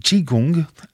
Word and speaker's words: Qigong: Eh bien, Catherine Roy, je vous remercie d'être venue nous parler de ces stages Qigong,